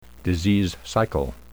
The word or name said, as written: disease cycle